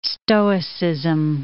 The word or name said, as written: Stoicism